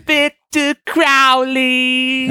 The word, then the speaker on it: Crowley: When you say the name, can you say it like the Aussie song Mr. Crowley? – Victor Crowley.